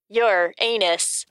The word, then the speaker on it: Uranus: Some people pronounce that name differently. – Yeah, I know. – In Latin, it would be pronounced your anus.